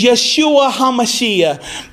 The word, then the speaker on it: Yeshua HaMashiach: Well, we must also remember that Jesus' Hebrew name is Yeshua HaMashiach. That is how you say it in Hebrew.